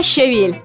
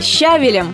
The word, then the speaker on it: щавель: Итак, на повестке дня щавель. Как бы нам не хотелось поставить под удар букву А и назвать щавель щавелем, очень не рекомендую этого делать, потому что это ошибка.